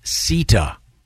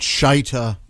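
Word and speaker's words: Shitta: Now, one of them, his first name is spelled S-H-I. TTA. I've done a little bit of research here. It may be pronounced Sita, depending on his ethnic origin. – Not Shita or the other one.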